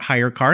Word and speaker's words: HyreCar: and why people need to learn about Hire Car. And I'll spell that for you listeners, HYRECAR, Hire.